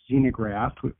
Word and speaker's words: xenograft: It's spelled with an X, xenograft, which is a graft from a different species.